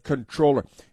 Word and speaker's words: comptroller: It's spelled comptroller, but controller.